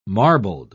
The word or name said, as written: marbled